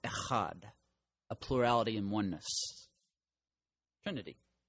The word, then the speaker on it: Echad: And then there's Echad. Again, if you say it right, you're going to spit on somebody. Echad.